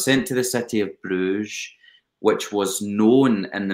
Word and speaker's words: Brugge: Now, I was eventually, I get sent to Bruges By the way that we pronounce that in Flemish or in Dutch is Brugge, believe it or not. And I can't believe I just said Bruges.